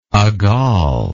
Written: agal

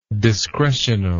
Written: discretionally

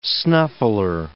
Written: snuffler

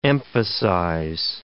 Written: emphasize

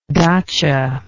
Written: datcha

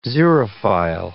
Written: xerophile

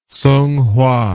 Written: Sunghua

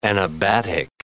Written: anabatic

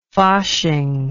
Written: fasching

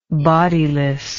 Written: bodilessness

